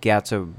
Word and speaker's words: Gyatso: Um, but then the former of his past guardian uh, monk, how do you say his name? – Guy. Um, Gaiatsu? Oh, gosh, uh, Gyatso. Gyatso. Gyatso. – Gyatso. Thank you.